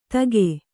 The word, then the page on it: ತಗೆ: Kannada